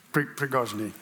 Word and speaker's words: Prigozhin: Prigozhin.